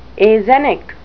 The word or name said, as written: axenic